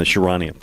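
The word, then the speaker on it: Charania: By the way, nice pronunciation on the Shams part. That's on the Sharania part. I've missed that many times myself.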